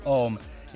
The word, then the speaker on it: Ohmme: I've been calling them Ohmme for a while, but I think you're right. I think they're called Ohmme. It's O-H-M-M-E.